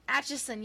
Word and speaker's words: Atchison: This city is so hard to pronounce. The Atchison? Okay, whatever, it's in Kansas. – Atchison? – Atchison, yes. I can't talk. – Atchison. Just say Atchison.